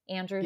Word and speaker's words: Andrew: My name is spelled A-N-D-I, Andrew, no S on the end.